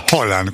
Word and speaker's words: Haaland: Que en noruego se pronuncia Haaland.